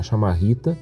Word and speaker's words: chamarrita: E desde a da, da chegada da chamarrita, né, uh, pronunciada desse jeito, né, como eu estou dizendo, chamarrita, como se fosse com CHA e não como CHI,